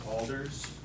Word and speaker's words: Alders: James Alders, A-L-D-E-R-S.